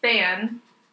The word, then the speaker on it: Thanh: It's literally L-E, Van like a vehicle, and Than like T-H-A-N-H.